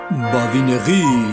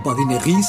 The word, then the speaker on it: Badinerie: Badinerí. Badinerí se escribe Badinerie y solemos pronunciar en, bueno, castellanizado, Badinerí en español.